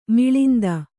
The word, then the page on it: ಮಿಳಿಂದ: miḷinda